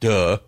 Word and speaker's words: LMDH: because he says pronounced duh, because simplified hybrid,